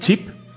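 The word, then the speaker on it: Ch'p: No sé cómo se pronuncia eso. – Chip. – Chip, ah, sí.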